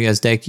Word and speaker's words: Gazdecki: First off, my name is Andrew Gazdecki. You pronounced my last name correct, so thank you for that.